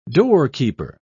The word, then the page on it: doorkeeper: British English